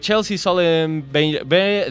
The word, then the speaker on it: Chesley Sullenberger: Estamos hablando de la historia de Chesley Sullenberger, perdón por mi inglés, tengo que trabajar en él. Chelsea Sullenberger. Ahí está. Ahora sí lo dije bien.